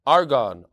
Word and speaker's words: Argonne: How do you pronounce this? Argonne National Lab. Argonne, that makes a lot more sense.